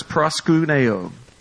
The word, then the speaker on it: proskuneo: The word says, this word is proskuneo. P-R-O-S-K-U-N-E-O. Proskuneo. All right, that's how you pronounce it.